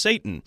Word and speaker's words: seitan: Yeah, and so it says it's apparently made from something called, I think, well, it appears to be pronounced Satan. It's S-E-I-T-A-N.